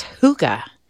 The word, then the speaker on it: hygge: It's hygge. Did I say it right, Carrie? – Yes, basically.